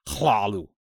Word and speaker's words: Cthulhu: They are kind of those unpronounceable things, just like Lovecraft's Hlalu, which is apparently the closest way human can say Cthulhu. Pronounce it correctly, it would be Hlalu.